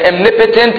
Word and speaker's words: omnipotent: Omnipotent and omniscient. Omnipotent. But the way it's pronounced is omnipotent, unfortunately.